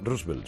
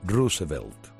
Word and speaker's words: Roosevelt: Pero es que además, si alguna vez habláis de Roosevelt, no se pronuncia Roosevelt, sino Roosevelt.